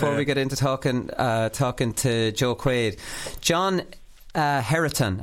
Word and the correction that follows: Heatherton: Have I pronounced that right? I haven't. – John Heatherton. Yeah, Heatherton. – Heatherton, is yeah. it? Right, John Heatherton.